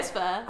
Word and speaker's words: chutzpah: Oh, is it chutzpah? – Yeah. – Is that how you say it? – Chutzpah.